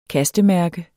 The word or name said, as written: kastemærke